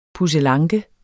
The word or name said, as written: pusselanke